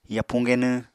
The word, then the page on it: yapuñ genwñ: Apatani